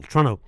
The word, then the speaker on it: Toronto: I remember you had to pronounce it Toronto, like it's one syllable, Toronto.